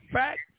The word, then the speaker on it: FAC: Yeah, it's Fact Marlowe. FAC standing for Family Always Counts. – Okay, now I knew what the FAC stood for, but I didn't know if you pronounced it out or if you acronymed.